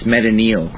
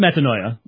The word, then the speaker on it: metanoia: How would you pronounce it? – It's Metanoia. – Okay. – So it is Metanoia. That's the verbal form.